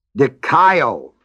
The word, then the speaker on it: dikaio: Its verb form is dikaio, D-I-K-A-I, O-O, last O is long.